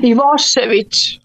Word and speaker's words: Ivošević: Ivošević.